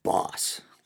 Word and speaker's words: Bosch: Everyone pronounces it like the power tool company. I think I once read it's supposed to be pronounced Boss. Like a boss.